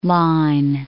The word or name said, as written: Line Islands